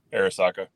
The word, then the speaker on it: Arisaka: Is it the Arisaka? – Yeah, Arisaka.